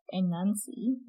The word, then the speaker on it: Anansi: And so these Anansi tales, or Anansi, which is A-N-A-N-C-Y, as it's spelled in Jamaica, are folk tales that are still passed down as oral traditions today.